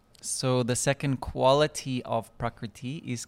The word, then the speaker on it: prakriti: So the second quality is prakriti. Prakriti. And prakriti can be s- broken up into its syllables. Pra, kri, iti.